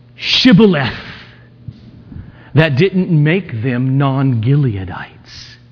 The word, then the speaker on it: Shibboleth: And when he said, no, they said to him, then say Shibboleth. And he said, Sibboleth. For he could not pronounce it right.